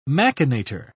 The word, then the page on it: machinator: British English